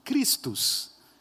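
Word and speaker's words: Chrestos: Se você vê em texto grego como que a palavra Crestos é escrita, ela é basicamente pronunciada como Cristos.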